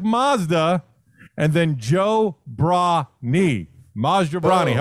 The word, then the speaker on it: Maz Jobrani: Well, I watched the thing that you described it as Maz Like Mazda, and then the name Joe, a bra like to hold up boobies, and then someone's knee.